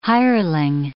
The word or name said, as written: hireling